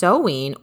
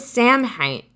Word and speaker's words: Samhain: So Halloween's origins date back to the ancient Celtic festival of Samhain, pronounced Sowin. What? I thought it was Samhain.